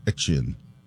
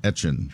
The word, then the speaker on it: Etienne: Yes, and so he said, because he's back in Louisiana and now playing for the Saints, that he would go by the pronunciation that they would say in Louisiana, which always was, like, Etienne. – So, it's Travis Etienne.